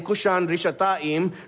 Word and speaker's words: Cushan-Rishathaim: In Hebrew, Mesopotamia there is the longer name is given, which is Aram-Naharaim, which rhymes with his name, Cushan-Rishathaim.